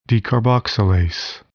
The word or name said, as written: decarboxylase